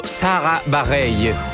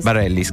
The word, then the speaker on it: Bareilles: Allora, io avrei detto Sara Barreille, un po' alla spagnola. – Ah, no. – Invece ho sentito che lei stessa si pronuncia Sara Bareilles. – Bareilles.